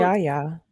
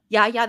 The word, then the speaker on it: Yahya: It's Yahya. – Yahya. That's what I was going to say.